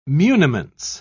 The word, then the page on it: muniments: British English